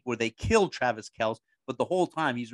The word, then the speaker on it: Kelce: Kelsey Travis Kelce. Everybody's always been saying his name wrong, Travis Kelce.